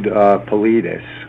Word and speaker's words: Paulides: Yeah, David Paulides, I believe is the way you pronounce his name. It's Paul, and then I-D-E-S.